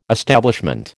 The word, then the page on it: establishment: American English